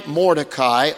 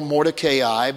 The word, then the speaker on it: Mordecai: Mordecai or Mordecai, both pronunciations are acceptable, but why Mordecai leans so much on being at the king's gate.